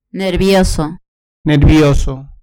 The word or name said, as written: nervioso